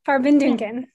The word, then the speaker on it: Farbindungen: And I know it's been a busy week for the two of you with, and I hope I've got the pronunciation of this correct, Farbindungen? – Farbindungen. – Okay, got it.